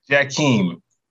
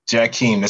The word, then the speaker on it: Jackiem: Jackiem. Jackiem. The second time was right. Yes.